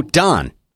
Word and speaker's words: Don: Oh, Don. How do you pronounce the sun coming up in the morning? Dawn. So, okay, say say those two again. Don and dawn. that sounds exactly the same to me.